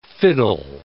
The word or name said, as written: fiddle